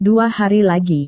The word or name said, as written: dua hari lagi